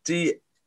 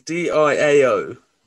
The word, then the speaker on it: Diao: It's Diao. It's Diao. – Diao. Salif Diao.